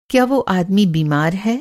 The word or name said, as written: बीमार